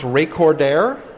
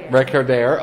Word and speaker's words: Recordaire: It's pronounced Recordaire. – Recordaire. – Okay. And there's a very specific, it kind of looks like recorder, and there's kind of a lot of metaphor and stuff going on there.